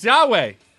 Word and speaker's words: Yahweh: It's Yahweh. Yeah. Let's talk about Yahweh. Well, let's do it. – Thank because you. Risen came out. – Thank you for not pronouncing the hard J.